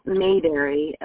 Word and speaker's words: Mayberry: It's pronounced Mayberry, but I'll spell it for you. – Okay. – It's like Ma, M-A. – Okay. – And then Berry, B-E-R-R-Y.